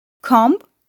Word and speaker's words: comb: Comb, climb, lamb ve tom gibi MB kombinasyonu olan bir kelimenin sonundaki B harfi sessizdir.